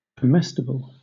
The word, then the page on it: comestible: British English